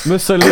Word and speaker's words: Mussolini: Did you just call it Miss Looney? – Miss Looney. – Mussolini.